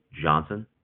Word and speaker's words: Johnson: Johnson. – And could you spell that for me, please? – J-O-H-N-S-O-N.